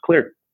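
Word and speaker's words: Xlear: And so, you know, then to paying homage to the xylitol, we changed out the C for an X and it's pronounced Clear.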